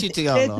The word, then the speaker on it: cetriolo: Sí, se li ve el cetriolo. – Cetriolo, cetriolo. – Cetriolo. – La C i la E es pronuncien che.